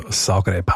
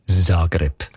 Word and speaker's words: Zagreb: Ich weiss, welche Richtung dass du willst, aber die spricht man eher mit S aus. Also Zagreb, Hauptstadt von Kroatien und eine riesen Fußballstadt. – Schreibt sie mit Z und sagt Zagreb.